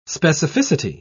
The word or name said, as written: specificity